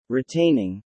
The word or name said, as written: retaining